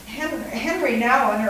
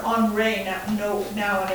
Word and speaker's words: Henri Nouwen: Henry Nouwen, or Henri Nouwen, if you pronounce it in the French way,